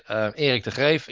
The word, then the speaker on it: Eric de Greef: My name is Eric de Greef. That's what the people pronounce it. Eric de Greef, if you pronounce it in Dutch, because I'm from the Netherlands.